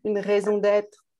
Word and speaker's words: une raison d'être: Yeah, une raison d'être.